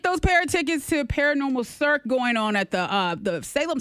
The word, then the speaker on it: Cirque: It's a Cirque. Yeah. C-I-R-Q-U-E.